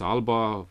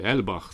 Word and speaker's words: Alba: Nu se pronunță Alba, pronunția este celtică, e Elbach, sau oricum ceva foarte, foarte greu de pronunțat de către noi, care difuzează cam 4 sau 5 ore doar programe în limba scoțiană, în gaelica scoțiană.